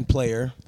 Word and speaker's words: Player: I'm gonna go ahead and say my name is Jovan Snowman Player.